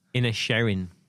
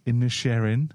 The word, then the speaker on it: Inisherin: Inisherin. – Inisherin. – That's how they say it.